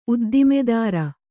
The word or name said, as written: ಉದ್ದಿಮೆದಾರ